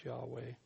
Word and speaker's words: Yahweh: And the best evidence seems to be that it's the pronunciation is Yahweh.